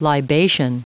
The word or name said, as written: libation